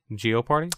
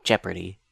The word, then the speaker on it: Jeopardy: Geoparty? Oh, Jeopardy. I hate you. I hate that you pronounce it like that because that is objectively incorrect.